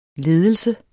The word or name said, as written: ledelse